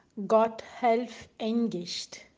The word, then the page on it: Gotthelf Engicht: German